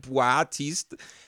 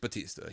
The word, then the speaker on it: Batista: They're like, you know, dumb Americans are like how do you say that Bautista? Just Batista.